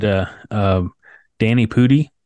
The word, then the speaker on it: Danny Pudi: And Mythic Quest is, it stars Rob McElhenney and Danny Pudi.